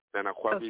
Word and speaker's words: Nana Kwabi: Nana Kwabi okay.